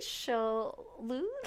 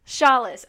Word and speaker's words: Shaulis: We have Sherri Shaulis. Oh, God, I just messed up the last name already. I forgot to check that. Darn! How do you pronounce it, Sherri? – It's Shaulis. – Shaulis, okay.